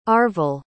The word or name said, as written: Arvil